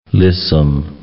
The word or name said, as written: lissom